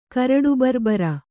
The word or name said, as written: ಕರಡುಬರ್ಬರ